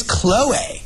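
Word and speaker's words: Khloé: It's Khloe. Khloe is the name. There's a little accent over the name.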